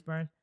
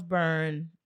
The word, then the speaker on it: Byrne: Also with actresses Ty Simpkins, Rose Byrne, I believe that's how you say her name, Rose Byrne. – It is. – Rose Byrne.